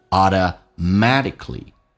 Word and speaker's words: automatically: 那么在美式发音经常会出现两个辅音在一起，通常前面一个辅音不用发音，就变成 automat，首先 T 浊化成的这个发音，两个元音中间所加的辅音要被浊化，叫 matty，而且 K 是不用发音，因为后面也是一个辅音，气流阻塞一下变成 automatically Automatically，可不发音 automatically，自然而然的，自发的。